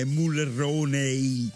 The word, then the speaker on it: Mulroney: Beh, ma lui è di origine italiana, Morroni. – Non è Morroni, è Mulroney. – Ah, scusa, lo leggi Morroni. – Perché si pronuncia così.